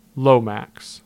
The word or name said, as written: Lomax